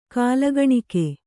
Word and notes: ಕಾಲಗಣಿಕೆ: kālagaṇike

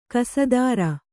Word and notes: ಕಸದಾರ: kannada

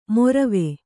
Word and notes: ಮೊರವೆ: morave

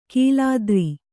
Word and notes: ಕೀಲಾದ್ರಿ: kīlādri

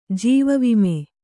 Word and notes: ಜೀವವಿಮೆ: jīva vime

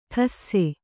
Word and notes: ಟಸ್ಸೆ: ṭasse

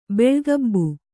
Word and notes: ಬೆಳ್ಗಬ್ಬು: Kannada

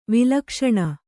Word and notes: ವೈಲಕ್ಷಣ: vailakaṣaṇa

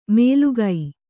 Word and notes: ಮೇಲುಗೈ: mēlugai; Kannada

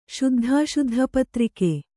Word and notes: ಶುದ್ಧಾಶುದ್ಧಪತ್ರಿಕೆ: śuddhāśuddha patrike

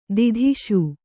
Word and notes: ಧೂತಿಸು: dhūtisu

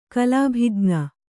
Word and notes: ಕಲಾಭಿಜ್ಞ: kalābhij'na

